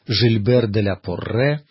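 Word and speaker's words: Gilbert de la Porrée: Или, как часто его именуют на современный французский манер, Жильбер де ля Порре. Иногда это имя произносит так, соединяя французский и латинский язык, Жильбер Пуританский. Но я думаю, что правильнее, наверное, об этом философе говорить как о Гильберте Пуританском.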